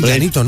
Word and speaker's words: llanito: Y pues se dice así, llanito, ¿no? – Llanito, sí, hay muchas palabras ahí.